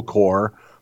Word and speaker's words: corps: I am a member of a small drum and bugle corps, then in parentheses, pronounced corps, that matches in local parades. So he made sure that I knew what C-O-R-P-S sounds like, but then he tells me that he matches in local parades, Tim.